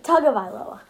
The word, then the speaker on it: Tagovailoa: I think it should be Tagovailoa or Tagovailoa. I'm sorry, Tagovailoa. That's how you say it, guys.